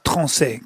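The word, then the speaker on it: Tronçais: So we like to work with wood from Troncay and Allier. – It's pronounced Troncay.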